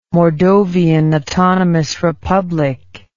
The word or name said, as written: mordovian autonomous republic